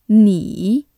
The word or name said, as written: 你